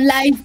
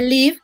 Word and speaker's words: Live: Y este, la cuarentena es el mejor momento, porque en Netflix está su documental que se llama Live se puede confundir con live como en vivo, pero no, se pronuncia live, que es de vivir.